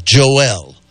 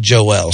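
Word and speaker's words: João: And I put in J-O-A-O, Barbosa, and every single translation was Joel. Like, it's got an L on there, but the L's not there.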